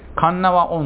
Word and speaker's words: 鉄輪: 神縄温泉、ね、鉄の輪っか、鉄輪と書いて神縄と読みます。